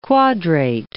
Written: quadrate

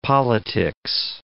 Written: politics

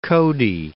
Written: Cody

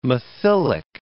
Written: methylic

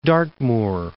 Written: Dartmoor

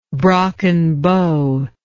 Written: brockenbow

